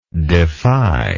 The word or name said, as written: defyingly